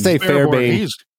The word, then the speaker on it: Fairbairn: You just say Fairbairn.